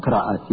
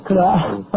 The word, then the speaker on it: Qiraat: Was the pronunciation right, first of all? – Qiraat, yes. – Qiraat, okay. Dr. Jamal, could you start off with a quick resume of last week's program, please? – Okay.